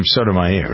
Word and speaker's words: Sotomayor: Sotomayor, right? – Right.